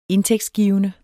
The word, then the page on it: indtægtsgivende: [ -ˌgiːvənə ]; [ -ˌgiˀvənə ]